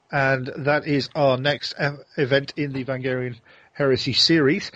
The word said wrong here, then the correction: Varangian: The Varangian. – Varangian series.